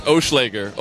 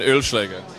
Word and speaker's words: Oeschläger: It's Oeschläger, or in German, Oeschläger.